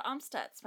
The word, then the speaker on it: Amstutz: All of my social media is on at Sandra Amstutz.